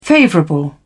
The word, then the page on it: favourable: British English